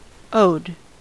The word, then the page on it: owed: American English